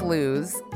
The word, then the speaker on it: Louis' Lunch: So Louis' Lunch is spelled L-O-U-I-S, like Louis, but it's pronounced Louis, sometimes Lou's.